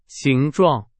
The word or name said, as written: 形状